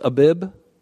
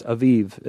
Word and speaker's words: Aviv: It looks like Abib. Abib. Looks like Abib. But it's Aviv, is how they pronounced it and keep the Passover to the Lord your God.